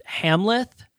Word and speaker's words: Amleth: And so knowing that going in the movie, the main character's name is Amleth, but it's pronounced Hamleth.